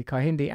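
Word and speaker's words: Kehinde: Oh, and Kihindi Andrews, or Kihindi is pronounced, to pronounce it correctly, Kihindi Andrews.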